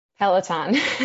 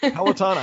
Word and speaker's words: Peloton: Peloton. – Peloton, I asked before I started.